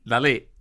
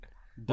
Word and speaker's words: Dalí: Dali. – Yeah, if you're Spanish. – I'm Spanish. – How do we say it? Dolly. What do we say? – I say Dolly.